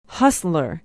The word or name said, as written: hustler